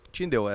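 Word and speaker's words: Tyndale: Uh, ah, Tindel, é assim que pronuncia, né? Tindei.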